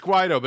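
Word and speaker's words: Guaidó: It's Guaido, but it's funnier as Guido.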